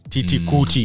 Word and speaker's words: Titi Kuti: Titi Kuti. – Yes, that's Titi, T-I-T-I, the same way you pronounce. – Yeah. – T-I-T-I.